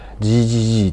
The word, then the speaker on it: GEE: GGG っていうですねあの GEE って書いて右上に 3GGG って読む